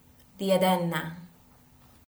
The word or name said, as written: Diadenna